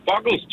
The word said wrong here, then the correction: Bugles: Bugles. – Præcis. – Bugles.